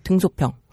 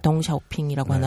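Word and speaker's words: 덩샤오핑이라고 (邓小平): 이제 우선은 이 중국 약간 변방 지역이고, 그 다음에 1984년이면 우리가 잘 알고 있는 그 등소평, 네, 덩샤오핑이라고 하나요? – 그렇죠. – 네, 요즘 발음으로는.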